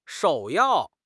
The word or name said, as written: 首要